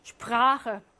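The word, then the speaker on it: sprechen: 슈프라헨은요, 원래는 짧아야 하지만 예외적으로 길게 아 하고 발음합니다. 슈프라헨.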